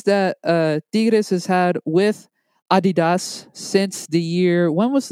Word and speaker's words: Adidas: Or if so you're European, Dennis, Adidas. – Adidas, yes. Sorry, I gotta ask for forgiveness for my German people for mispronouncing there.